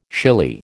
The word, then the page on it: shily: American English